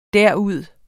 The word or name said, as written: derud